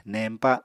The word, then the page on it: neñpa: Apatani